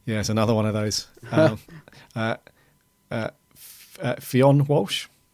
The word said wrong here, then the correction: Fionn: Oh, it's Finn. – Finn. – Yeah, oh, I asked him.